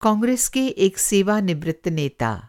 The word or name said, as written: सेवानिवृत्त